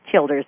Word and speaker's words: Childers: It's spelled like Childers, and it is Childers.